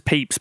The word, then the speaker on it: Pepys: His name is Pepys, but it's spelled Pepys.